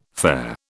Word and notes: fare: British English

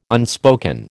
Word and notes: unspoken: American English

